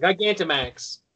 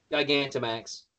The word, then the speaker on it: Gigantamax: Gigantamax. Gynamax. – Vaginamax, yeah. – Okay, say it again so I can say it right. – Gigantamax.